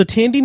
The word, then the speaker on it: Thandie: Thandie Newton, who apparently her name is pronounced Tandy. – Tandy Newton.